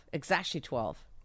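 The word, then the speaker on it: X Æ A-12: And she named the kid X-A-E symbol A-12. – All right, so he elaborates on that. – Letter X, and then the A-E is like pronounced Ash, and then A-12 is my contribution.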